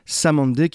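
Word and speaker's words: Cymande: Samandé qui s'écrit Simande, rappelez-vous.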